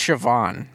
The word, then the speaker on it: Siobhan: It was spelled like S-I-O... S-I-O-B-H-A-N. – Oh, yeah. That's how they're... That's, you know, who knows? They said it... I think it's Siobhan. – Oh, it is Siobhan. Whoa.